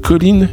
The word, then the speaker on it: Colleen: Nous sommes ensuite revenus à notre album de la semaine, le nouvel album de la française Colline. Colline, ça s'écrit C-O-L-L-E-E-N.